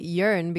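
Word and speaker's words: Jørn: Yeah, and so, I like that you said it Yorn because apparently that is actually how you say his name.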